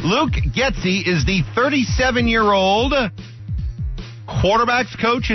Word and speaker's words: Getsy: Luke Getsy. – Getsy. Spell that. – G-E-T-S-Y.